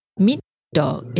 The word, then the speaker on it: middag: Dinner, in Swedish, we say middag. Middag. It's spelled M-I-D-D-A-G.